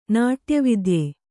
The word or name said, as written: ನಾಟ್ಯವಿದ್ಯೆ